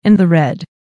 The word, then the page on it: in the red: American English